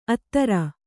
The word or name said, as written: ಅದೂರ